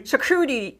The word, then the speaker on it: charcuterie: Charcuterie? – Charcuterie.